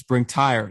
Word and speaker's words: Bring Tire: One of the upcoming projects I know that, which I saw from Richard Dean and which he sent me over, was Bring Tire. Is that how you pronounce it? – Yeah. I know it's spelled, well, just the English spelling of tire,